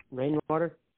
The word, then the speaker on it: Rainwater: And then first name is spelled N-A-N-C-Y? – Correct. – Perfect. And then last name? – Rainwater. – How do you spell that? – R-A-I-N-W-A-T-E-R.